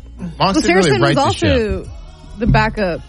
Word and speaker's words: Saracen: Saracen.